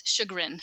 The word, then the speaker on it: chagrin: Is it Chagrin or Chagrin? – It's Chagrin. – Chagrin.